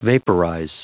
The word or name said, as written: vaporize